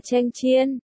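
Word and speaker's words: chanh chiên (强箭): Từ cường tiễn, đọc là chanh chiên, hài âm hoàn toàn với cưỡng gian,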